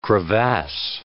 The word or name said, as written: crevasse